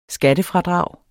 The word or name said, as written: skattefradrag